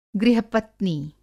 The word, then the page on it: गृह-पत्नी: Hindi